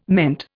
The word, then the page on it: meant: Indian